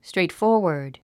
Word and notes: straightforward: strèitfɔ́ː r wə r d; ストレイトフォーワァド

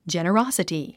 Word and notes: generosity: dʒènərάsəti; ジェネロォソティ

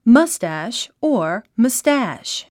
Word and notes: moustache: mʌ’stæʃ; マスタァシュ

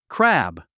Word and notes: crab: krǽb; クラブ

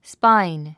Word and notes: spine: spáin; スパァイン

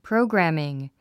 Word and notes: programming: próugræmiŋ; プロォグラミング

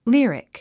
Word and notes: lyric: lírik; リィリック

